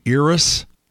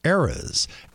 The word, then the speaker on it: Eras: They're saying Eras and variations of Eras. And it's not. It's Eras, as in the plural of era.